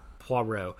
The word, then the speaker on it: Poirot: I think she wrote, like, 50 Poirot novels, or Poirot, or I keep saying his name wrong. I'm so sorry. – Poirot.